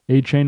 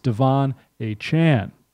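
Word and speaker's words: Achane: It's Devon A-Chan. Earlier player pronunciation guides released by the Dolphins list A-Chain with the Chain pronunciation. This has since been updated, and the team's media site now includes a recording of A-Chain pronouncing it with the Chan ending.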